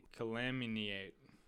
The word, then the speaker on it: calumniate: I'm looking at the phonic version here, which is K schwa L schwa M N long E long A T. So that would be calumniate.